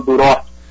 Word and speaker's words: Duroc: Primeiro já me corrija se eu falei certo a pronúncia dessa carne. É isso mesmo? É Duroc que fala? – Correto, é isso mesmo. É o suíno Duroc.